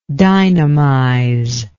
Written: dynamization

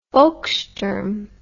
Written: volkssturm

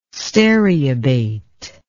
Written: stereobate

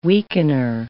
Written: weakener

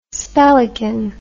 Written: spellican

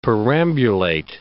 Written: perambulate

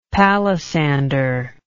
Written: palisander

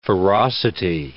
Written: ferocity

